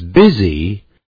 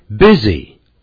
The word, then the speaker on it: busy: 我们可以说这个月中他都很忙， He is busy during the month。 Busy， B-U-S-Y， B-U-S-Y，忙碌的。这个字可不要念成 busy，不要按照这个字母，大概是念成 busy，那就不对了。